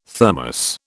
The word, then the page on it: Thermos: British English